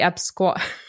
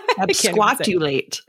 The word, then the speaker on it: absquatulate: But the, I'm going to butcher it, but the Epsquat. – Absquatulate.